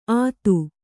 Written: ಅದು

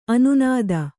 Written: ಅನ್ನದ

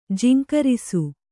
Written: ಜೇಂಕರಿಸು

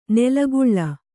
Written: ನೆಲೆಕೊಳ್

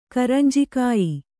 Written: ಕರಂಜಿಕಾಯ್

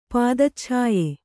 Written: ಪಾದಚ್ಛಾಯೆ

